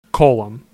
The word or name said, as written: Cullom